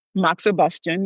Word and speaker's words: moxibustion: Moxibustion. – Moxibustion.